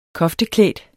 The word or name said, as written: kofteklædt